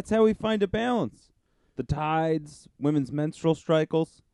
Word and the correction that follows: cycles: Menstrual cycles.